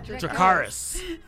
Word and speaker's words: Dracarys: Wait, how do you say that? – Yeah, Dracarys.